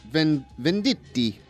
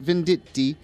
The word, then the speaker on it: Venditti: So it's Robert Venditti. I'll say it like, I forgot her name on the Food Network. She says spaghetti. So I'll say Robert Venditti.